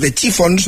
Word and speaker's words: The Chiffons: Años antes había triunfado otra creación, He's So Fine, compuesta por Ronald Mack e interpretada por The Chiffons, no sé si lo he dicho bien.